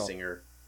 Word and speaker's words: Basinger: Like, but Patrick pointed it out or brought it up, so it is worth doubling back to Kim Basinger or Basinger. I never know how to pronounce her name. As Vicky Vale. – Basinger.